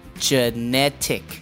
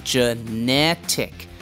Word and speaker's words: genetic: 那第二个字呢，就是 mosquito.那 mo 发音为 mosquito, 然后的 q 我们的发音是 k, 那 mosquito 的话有三个音节，就变 mosquito, 所以你听到那个 q 越跟 k, mosquito, 然后我们做一个字呢，就是 genetic, 那第一个 e 它发音是为然后第二个 e 才发 a, 所以变 genetic, 所以你听到那个前面的 e 就变 j,然后 genetic, 然后我们的那个 C 呢也是发 K 的音，因为我们这个叫做 hard C, hard C 的话通常都是发 K 的音，所以变 genetic 有三个音节 genetic.